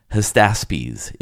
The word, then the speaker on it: Hystaspes: Hystaspes is how you say the entire name.